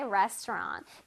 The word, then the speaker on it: restaurant: Come to my restaurant. 자, restaurant 발음 한번 해볼까요? Restaurant 아닙니다. Restaurant 아니고요. Restaurant. Restaurant. 끝에 랑 소리가 아니라 응 소리를 내주세요. Restaurant.